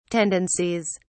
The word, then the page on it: tendencies: British English